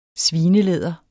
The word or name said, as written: svinelæder